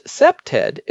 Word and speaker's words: CPTED: The field of crime prevention through environmental design, CPTED, pronounced SEPTED, is a whole sub-discipline.